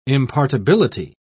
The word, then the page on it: impartibility: British English